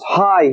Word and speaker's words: Ai: It's pronounced high here, but it's still the same place, Ai.